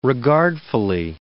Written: regardfully

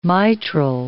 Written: mitral

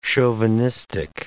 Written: chauvinistic

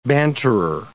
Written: banterer

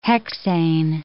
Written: hexane

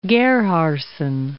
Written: Gerhardsen

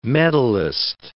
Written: medalist

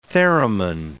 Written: theremin

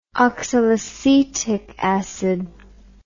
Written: oxalacetic acid

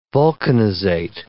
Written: vulcanizate

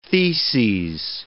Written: theses

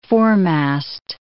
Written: foremast

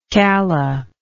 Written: calla

